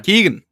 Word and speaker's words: Keoghan: And I know obviously we get some pretty brutal finger moments, I don't know about you, but I was waiting for, is it Barry Keoghan? Is that, you must know. – Keegan, there you go.